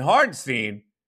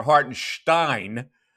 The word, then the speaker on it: Hartenstein: And Hardenstein, or Hartenstein, as you want, if you want to pronounce it correctly, follows the play astutely and gets a block on Maxey.